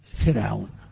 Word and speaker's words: فرعون: فرعون، نطقها فرعون، رقق الراء، لاحظتم معي؟ أنا أقول للجميع هذا الكلام، كلمتان يكثر الخطأ فيهما، فرعون ومريم، يخطئ كثير من الناس فيفخم راء فرعون ويرقق راء مريم، يا مريم، ويرقق راء مريم فيقول يا مريم، يا مريم اقنتي لربك. والصواب العكس فيهما، الصواب العكس فيهما.